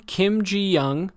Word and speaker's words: Kim Ji-young: So as a heads up, the next three books in order that we'll be covering them are one, Kim Ji-young, born 1982, by Cho Nam-ju. How did I do? Not good? – Yeah, it was good.